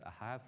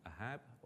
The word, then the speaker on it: Ahab: Now, in the delights of the Hebrew language, a B is actually usually pronounced as a V, so that actually says Ahav, not Ahab or Ahab, as I was trying to read it.